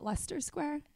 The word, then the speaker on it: Leicester Square: The one night that we were out in Leicester Square, as we found out last night, that's how you pronounce it, Leicester Square. – They corrected you and they were like Leicester. I don't know. – I said Leicester and I was like, it's so American of us to pronounce every letter.